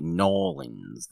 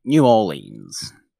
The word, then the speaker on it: New Orleans: Well, if I were to call it Norlins, then I would be doing a ridiculously inaccurate impression of somebody from that city. So I call it New Orleans. Because that's how it's pronounced in my mind and in my voice.